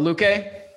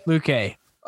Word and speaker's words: Luque: Oh, Luque. Luque.